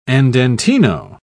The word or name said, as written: andantino